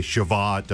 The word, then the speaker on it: Shavuot: Is this what most people just call Shavuot when you hear, like, on television or something? – Certainly. – It'll say Shavuot. Okay. – Yeah, yeah. So Shavuot is the Feast of Weeks, or Pentecost. Shavuot is simply the Hebrew word for weeks.